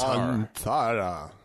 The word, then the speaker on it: Santhara: Santhara. Or Santara. – Santara. – Yeah, something like that. – You have to say it like that? – Yes, you do.